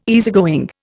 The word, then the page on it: easygoing: Indian